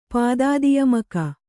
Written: ಪಾದಾದಿಯಮಕ

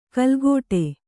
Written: ಕಲ್ಗೋಟೆ

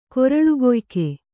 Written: ಕೊರಳುಕೊಯ್ಕ

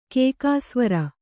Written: ಕೇಕಾಸ್ವರ

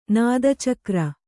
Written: ನಾದಚಕ್ರ

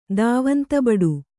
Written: ದಾವಂತಬಡು

